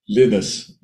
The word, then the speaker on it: Linus: To the point where Linus released a clip of audio of him saying, "Hello, I'm Linus Torvalds," and Linus is pronounced Linus, Linux.